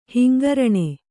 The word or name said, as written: ಹಿಂಗರಣೆ